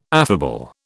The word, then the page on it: affable: British English